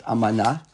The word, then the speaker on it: amana: I think the word is pronounced amana, right? And I'm just thinking to myself now. Yes, ba'amana.